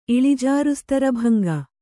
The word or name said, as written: ಇಳಿಜಾರುಸ್ತರಭಂಗ